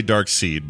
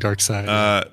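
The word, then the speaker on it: Darkseid: I always say dark seed, but it's pronounced side. Anyway. – Dark seed, dark side.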